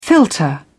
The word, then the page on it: filter: British English